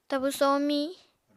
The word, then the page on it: tabu somiñ: Apatani